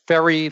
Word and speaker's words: Ferri: and Rick, just for clarification out there, your last name is spelled F-E-R-R-I. – That is correct. Ferry, like a ferry boat with an I.